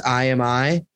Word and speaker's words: IAMEYE: The group name is spelled I A M E Y E, and it's pronounced I am I.